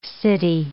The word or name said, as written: city